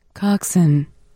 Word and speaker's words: coxswain: How do you pronounce C-O-X-S-W-A-I-N, please? – Coxswain. – Do it again? – Coxswain. – Oh. Not Swain.